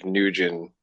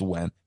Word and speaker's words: Nguyen: And yeah, man, it's hilarious because everyone's seen that name and they're like, how the fuck do you pronounce that? But yeah, it's Wen.